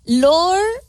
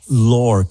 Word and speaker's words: Lore: Vienes representando Lore o Lore, ¿cómo dices? – Lore, como folklore. – Ándale, sí, pero se escribe Lore. – Es como folclórico. – Como folclórico. – Pero es lore.